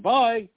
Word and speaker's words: BIE: And thinking about it, when you pronounce B-I-E, you're saying bye, as in what you say to some fool when you put their rear end on an airplane and wave goodbye to them.